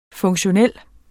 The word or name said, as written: funktionel